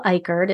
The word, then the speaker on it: Icard: If you're on Instagram, it's just my full name, Michelle Eichard. It's two L's and it's spelled like Icard, I-C-A-R-D.